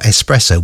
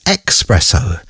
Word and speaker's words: espresso: Sarah Louise Blunden says, I always thought espresso was expresso.